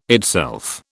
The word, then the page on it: itself: British English